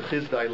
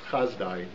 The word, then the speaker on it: Chizdai: It's Appendix E on page 635, and it starts with the Chizdai letters. Sometimes it's pronounced Chazdai. Different historians either pronounce it Chizdai or Chazdai.